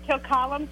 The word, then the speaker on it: Kilcollen: It's Kilcullen, K-I-L-C-O-L-L-E-N.